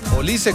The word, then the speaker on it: Olise: Pero el apellido siempre va a ser Olise. – Olise, con el acento en la I.